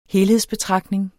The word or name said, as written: helhedsbetragtning